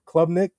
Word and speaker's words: Klubnik: Uh, was it Club Klubnik? I believe that's how you pronounce it.